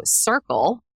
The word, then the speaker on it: Cirkul: But I recently invested into Circle, and that is spelled C-I-R-K-U-L.